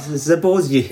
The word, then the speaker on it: Zaporozhye: Zaporogi. qu'est-ce que... Comment, Zaporogi, c'est pas ça Zaporozhye. Zaporozhye Voilà.